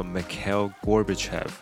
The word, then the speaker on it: Mikhail Gorbachev: 好，那呃，英文的话我们会念作 Mikhail Gorbachev。